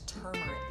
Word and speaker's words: turmeric: Well, first of all, there's another R in there. It's turmeric.